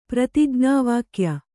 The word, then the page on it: ಪ್ರತಿಜ್ಞಾವಾಕ್ಯ: prati jñā vākya